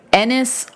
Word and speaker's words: Ennis: Yeah, and Ernest and Jack kind of go their separate ways after that, because Ennis Delmar. Ennis. – Heath Ledger. – I remember that's how you say it. Ennis.